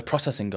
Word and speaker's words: processing: We're back with the processing guy. I can't say it in English now. – I feel like you need to say it's processing. You need to put emphasis on the O.